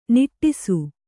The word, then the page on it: ನಟಿಸು: naṭisu